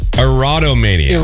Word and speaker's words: erotomania: There is a condition called erotomania. And I think I'm pronouncing that right. Let me just check here. No, erotomania. Erotomania.